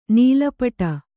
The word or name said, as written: ನೀಲಪಟ